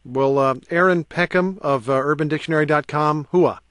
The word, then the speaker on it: hooah: Hooah. Uh-huh. H-O-O-A-H.